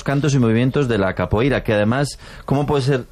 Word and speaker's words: capoeira: Pues todos sabemos lo que es la copeira, ¿verdad? Capoeira. Qué mal pronuncio últimamente.